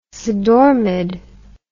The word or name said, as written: sedormid